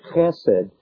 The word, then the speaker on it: hesed: In the Hebrew, the word that's used for steadfast love is a word spelt in our language, H-E-S-E-D, and it's pronounced with a guttural H, which would sound like chesed.